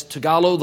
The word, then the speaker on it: Tagalog: And then it's Tagalog, the language, and it's lumpia, what you eat.